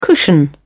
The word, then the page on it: cushion: British English